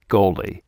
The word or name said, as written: goalie